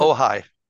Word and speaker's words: Ojai: Ojai, is that how they pronounce it? – Ojai. – Ojai, excuse me, Ojai.